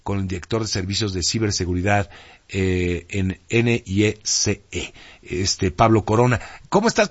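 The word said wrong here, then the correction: NYCE: ¿Lo dije bien en el CIE o cómo se dice? Nada más para tenerlo bien. – NICE, en realidad es NICE.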